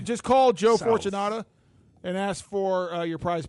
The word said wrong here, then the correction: Fortunato: You got his name wrong too. It's Joe Fortunato. – Joe Fortunato.